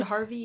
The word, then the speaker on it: HRVY: I don't know how to pronounce it. – Oh, Hervey? He's good. Harvey. – Harvey.